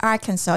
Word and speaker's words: Arkansas: I know, Apparently, it's because of the French, isn't it? So don't pronounce the S, so it's Arkansas. I didn't know that. But then Kansas is Kansas.